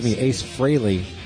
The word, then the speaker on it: Frehley: Even though Gene sang it, that was an Ace, Ace Frehley. I always forget. It rhymes with Haley.